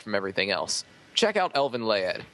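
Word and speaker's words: Elfen Lied: Remember, Elvin Laed. It's spelled like Elvin Lied, but it's pronounced Laed.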